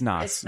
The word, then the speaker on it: Nas: Is it Nas? – I mispronounce anything. – It's Nas. Yeah.